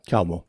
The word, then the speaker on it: Carmel: And then we get Lenny with Mount Carmel. Is it Carmel? Yeah, Mount Carmel. Is that right? – Carmel. Yes. Yeah.